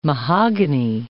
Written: mahogany

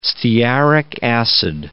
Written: stearic acid

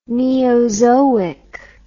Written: neozoic

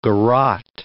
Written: garotte

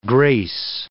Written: grace

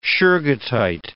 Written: shergottite

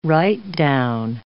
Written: write-down

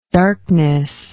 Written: darkness

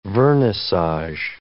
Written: vernissage

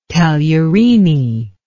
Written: tagliarini

